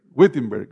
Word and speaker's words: Wittenberg: Wittenberg, gracias hermanos.